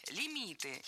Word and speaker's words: лимит: А подвох в том, что слово это латинское. А пришло в русский язык оно из французского. И сохранило ударение французское на последнем слоге – лимит.